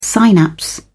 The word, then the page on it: synapse: British English